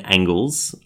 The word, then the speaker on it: Angles: Uh, for example, the Order of the Nine Angels. Uh, sorry, the Order of the Nine Angles. I always mispronounce it. I want to say angels, but no. Order of the Nine Angles uh,